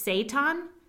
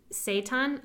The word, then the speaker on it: seitan: I think it's seitan, S-E-I-T-A-N, seitan. All I'm thinking is Satan.